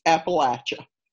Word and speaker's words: Appalachia: I pronounce it Appalachia, the latch.